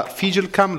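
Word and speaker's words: FIJLKAM: E Andrea Sozzi, presidente del Kodokan Cremona e della Figilcam Lombardia, è detto giusto? – Sì, settore judo.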